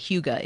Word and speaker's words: hygge: The book is called Holy Huga, and that's spelled H-Y-G-G-E, Huga is.